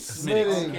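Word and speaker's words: Smithwick's: Smittix. Oh, so you don't pronounce the K.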